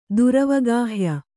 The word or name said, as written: ದುರವಗಾಹ್ಯ